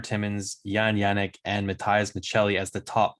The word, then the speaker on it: Maccelli: And it's Matthias Michelli. That's the pronunciation there.